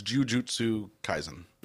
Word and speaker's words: Jujutsu Kaisen: I'm pretty sure it's Jujutsu Kaisen.